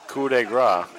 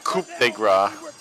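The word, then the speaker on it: coup de grâce: Yeah, at least he knows how to pronounce it. Coup de grace. Not coup de grace. Like Corey Graves likes to say.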